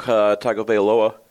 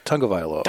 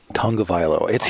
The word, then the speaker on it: Tagovailoa: I mean, unless we broke Tagovailoa. – I did it! I said it right this time! – No, you didn't. – I didn't. – Tagovailoa. – Darn it. – Tagovailoa.